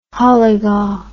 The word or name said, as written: holliger